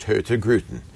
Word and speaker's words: Hurtigruten: It's spelled Hurtigruten. – Yes. – And in Oslo, it would be pronounced Hurtigruten. – Uh-huh. – But in Kristiansund, where we were on the West Coast, they have a different accent. And they drop the N and they drop the R.